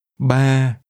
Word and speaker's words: Ba: Ba. Ba. Ba. – 3 is pronounced with a mid-level tone.